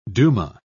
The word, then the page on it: duma: British English